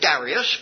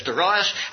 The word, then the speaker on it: Darius: And the outstanding feature of the decree of Darius, some pronounce it Darius, but I notice David says Darius, and I'm very happy to follow him.